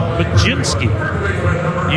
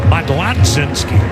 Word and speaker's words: Mlodzinski: You pronounce it Majinski. You spell it like it should be pronounced Modlodzinski. M-L-O-D-Z-I-N-S-K-I. Pronounced Majinski.